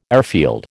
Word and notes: airfield: American English

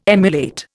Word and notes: emulate: Indian

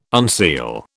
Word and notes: unseal: British English

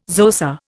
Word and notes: Xhosa: Indian

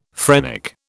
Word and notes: phrenic: British English

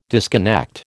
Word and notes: disconnect: American English

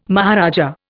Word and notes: maharaja: Indian

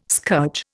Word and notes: scourge: Indian

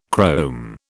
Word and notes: chrome: British English

